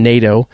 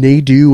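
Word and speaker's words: Nadeau: Just think of it as N-A-D-O, Nato. It's very easy to say. Everybody is completely just terrified of mispronouncing it, so they call him Nadu or Nadow. It's just Nato.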